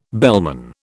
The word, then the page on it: bellman: British English